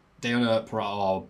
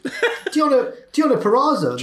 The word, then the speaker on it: Purrazzo: And he'd need it because Nia Jax is back and she squashes Deonna Purrazzo. Oh, butchered that name. Her opponent to celebrate. – Deonna Purrazzo, the virtuoso.